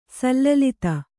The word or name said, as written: ಸಲ್ಲಲಿತ